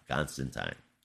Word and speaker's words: Constantine: But yeah, Constantine.